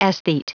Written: aesthete

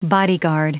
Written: bodyguard

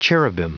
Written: cherubim

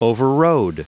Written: overrode

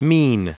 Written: mien

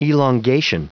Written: elongation

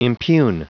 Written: impugn

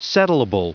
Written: settleable

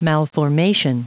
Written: malformation

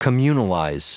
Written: communalize

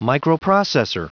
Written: microprocessor

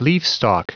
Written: leafstalk

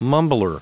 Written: mumbler

